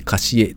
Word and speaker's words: CASIE: それから、現代アートのサブスクリプションサービス、カシエ CASIE でカシエと読むんだそうですが、こちらのサービスを展開する株式会社カシエ。